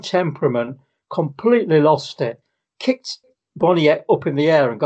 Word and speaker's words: Boniek: Boniek, yeah. – How you pronounce? I know Boniek was right.